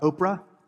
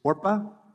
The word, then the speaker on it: Orpah: Verse 3 tells us that Mahlon and Chilion decided to marry Moabite girls, one named Orpah and the other Ruth. Just want to note for you that her name is not Oprah, it is Orpah.